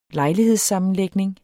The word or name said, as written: lejlighedssammenlægning